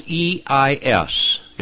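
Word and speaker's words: E-I-S (εἰς): Now, the little word "for" is a Greek preposition that is spelled E-I-S. It's pronounced just like ice, like you would have ice cubes, only it's E-I-S in the Greek, and it should be translated "because of."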